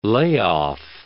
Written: layoff

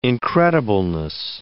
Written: incredibleness

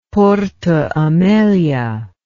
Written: Porto Amelia

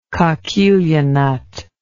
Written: coquilla nut

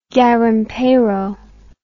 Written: garimpeiro

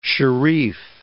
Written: sharif

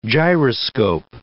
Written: gyroscope

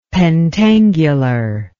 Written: pentangular

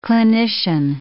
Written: clinician

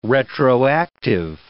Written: retroactive

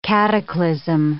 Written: cataclysm

